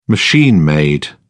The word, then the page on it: machine-made: British English